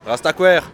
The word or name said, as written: Rastakwer